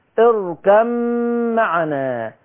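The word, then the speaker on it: اركب معنا: انا بتكلم كلام يفهمه الجميع، الباء ما بتتنطقش، هتنقل من الكاف على مين؟ على الميم، اركم معنا.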